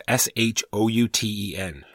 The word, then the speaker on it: Shouten: Joey Shouten. It's S H O U T E N.